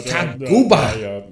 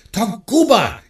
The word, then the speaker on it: Taguba: General Tabuga, do we... Senator, it's Taguba. That's it. Taguba. It's only three syllables. It's not hard.